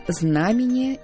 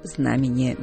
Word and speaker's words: знамение: Что до ударения, то здесь все просто. Знамение и только знамение. Никаких вариантов нам никто даже и не предлагает. Знамение времени или небесное знамение – все равно оно будет знамение.